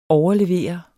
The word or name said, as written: overlever